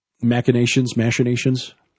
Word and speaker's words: machination: For example, the ongoing situation in the Middle East with ISIS and Iraq, political machinations. I never know how to pronounce that word. – You know, it's machination. – Machination? – Yes. – Yeah. – It is. Well, technically, it should be.